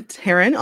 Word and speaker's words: Taryn: So yes, I'm Taryn Almendarez. It is pronounced like Karen with a T.